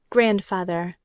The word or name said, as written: grandfather